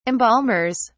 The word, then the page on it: embalmers: British English